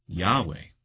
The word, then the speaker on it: Yahweh: And His name, as I've come to understand it, is pronounced Yahweh.